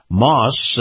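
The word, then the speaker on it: Maus: Now, this portion of the program is brought to you by Moss, that's M-A-U-S, Acura. That's spelled A-C-U-R-A.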